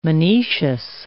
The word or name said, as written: monoecious